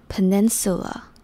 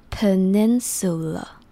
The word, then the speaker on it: peninsula: 接下来，nin 是 nin，然后 sula 的 su 是短一点点，peninsula，最后的 la 不用念成 la，只要轻轻念成 l 的感觉就可以，所以 peninsula，那念快一点点 Peninsula。